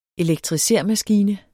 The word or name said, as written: elektrisermaskine